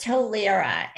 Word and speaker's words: Tollerra: Well, so first tell us how to pronounce, is it Tollerra or Tollerra or how is it? – It's Tollerra, and it's Tollerra. Actually a made up word.